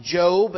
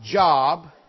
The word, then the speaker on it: Job: I know that it's pronounced Job and not Job because 6% of Americans wouldn't be able to find it.